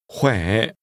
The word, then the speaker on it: khỏe: Chị có khỏe không? Khỏe. Khỏe. – Is pronounced with a tone which first falls, then rises.